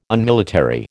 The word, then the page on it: Unmilitary: American English